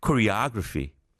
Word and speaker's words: choreography: 이 photography에 이제 사촌들이 있는데 choreography, 이 안무를 choreography. 그러니까 the accent is on the O. 두 번째 음절. Photography, choreography.